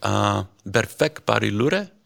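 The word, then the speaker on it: Berfek Barî Lure: The first song that you played, Berfek Bari Lure, am I pronouncing that right? – Yeah.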